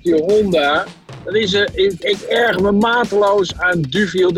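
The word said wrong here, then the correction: Deauville: Dat ding heet gewoon Doviel.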